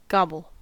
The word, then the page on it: gobble: American English